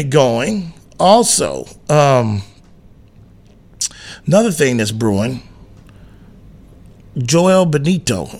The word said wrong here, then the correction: Bitonio: Is that right? Did I butcher that one, Jonas? You know who that is, right? Joel Bitonio? Yeah, I butchered that one.